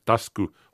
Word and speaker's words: Tasuku: Allison, mm, USA och Tasku, Tasku det, det skrivs Tasuku men uttalas Tasku Honjo.